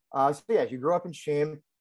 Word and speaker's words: Cheam: Now, I'm going to try to pronounce this correctly. Cheam? – Yeah, you got it.